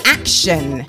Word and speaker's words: Akshen: He began his career as DJ Action, which is spelt A-K-S. H-E-N, DJ Action, which was a backronym for the phrase "all crazy expletive has ended now."